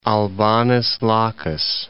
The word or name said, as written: Albanus Lacus